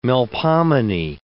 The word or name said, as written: Melpomene